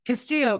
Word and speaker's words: Castillo: Castillo.